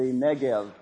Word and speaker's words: Negev: along with the area called the Negev, or the Negev, as it's pronounced today.